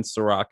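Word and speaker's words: Serak: If that should work, but if it doesn't, or if you have issues, just type my name in Serac. It's S-E-R-A-K. I know, unfortunately, not spelled like the liquor, but the show is way better.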